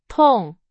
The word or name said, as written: 痛